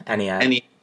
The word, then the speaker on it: Ennead: Iliad. That's how you pronounce it? – Ennead.